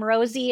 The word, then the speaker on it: Rosy: So the biggest thing to remember is that my name Rosie is spelled like Rosy Cheeks. So R-O-S-Y. So My other name of my website is tattooed on the other part of my body.